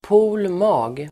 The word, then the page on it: pol mag: [po:l ma:g]